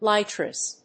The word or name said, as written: litres